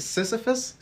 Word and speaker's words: Sisyphus: And one of them was called Sisyphus's Wish or something like that. – Oh, okay. – How do you say it? Sisyphus? – Sisyphus, yeah.